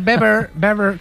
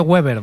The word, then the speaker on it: Weber: Está Manfred Weber, que se dice Weber, sí. No se dice Weber, ¿vale? – Sí, sí. – Se dice Manfred Weber.